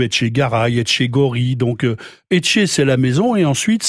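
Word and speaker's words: etxe: la maison qui se prononce Etché.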